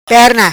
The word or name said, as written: Perna